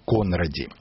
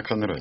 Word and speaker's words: Конради: Словарь фамилий, словарь имен собственных, прошу прощения, дает ударение Конради. Но я нашел интервью с одной современной носительницей фамилии. – Она Конради.